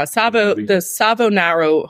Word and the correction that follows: Savonarola: Hotel. We'll leave the link in the description. Savonarola? I can't pronounce anything. – Savonarola Hotel.